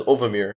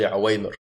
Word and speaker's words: Uwaymir: His name is, at first I used to pronounce it as Ovamir, because that's how he spelt it. Until I later realized his name is actually Uwaymir.